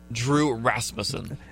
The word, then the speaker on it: Rasmussen: Drew Rasmussen. – It sounded like you said Raspin. I was like, that didn't sound right. – I did. I almost went back and said it again, but Drew Rasmussen.